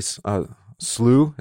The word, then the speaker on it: slough: How does he say it? The miry slough? Is that how you pronounce it? – It's slough, yeah. – Oh, it's slough. Look at that. – That's how you pronounce slough. It's also how you pronounce slough, which makes it even worse.